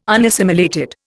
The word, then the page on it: unassimilated: Indian